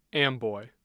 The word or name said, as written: amboy